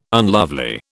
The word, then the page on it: unlovely: British English